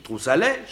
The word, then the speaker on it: Troussalet: Comment avez-vous prononcé? – Ben comme ça s'écrit, Troussalet, château de Troussalet.